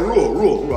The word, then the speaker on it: Rhule: Matt Rhule. I want to say Maul. Yeah, so Matt Rhule, and then you got Judge with the Giants.